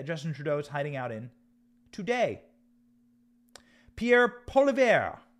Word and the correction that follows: Poilievre: I'm going to need to work on my French name pronunciations. Pierre Poilievre is a man who has announced that he will be running for prime minister against Justin Trudeau.